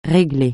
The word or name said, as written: réglée